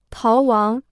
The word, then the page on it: 逃亡: táo wáng